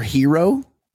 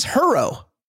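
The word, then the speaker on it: Herro: And the Heat have that guy, Tyler Hero. – Yes. – But it's spelled H-E-R-O. It's not Hero.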